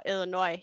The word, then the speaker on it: Illinois: Illinois, yeah. – Okay. So, yes, I'm going to Illinois.